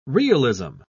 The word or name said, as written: realism